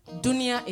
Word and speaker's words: dunia: Dunia is world. That's how we pronounce dunia in Sicily.